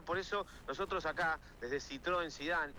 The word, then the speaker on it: Citroën: Citroën, no, Citroën es Citroën. No es Citroën.